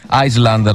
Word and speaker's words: Island: Island, la S non si pronuncia.